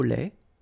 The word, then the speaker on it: lait: Mille, mille, camomille, une camomille, un thé au lait, final T not pronounced, un thé au citron, un thé glacé.